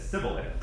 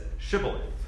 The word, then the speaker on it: Shibboleth: And one side of the river says Sibboleth, and the other side says Shibboleth.